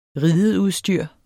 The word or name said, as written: rideudstyr